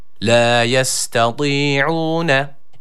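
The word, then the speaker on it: La yestati'une (لَا يَسْتَطِيعُونَ): Öncelikle tı harfine dikkat ediyoruz. I arası bir sesle bir elif miktarı uzatıyoruz. Kalın bir harftir. – La yestati'une.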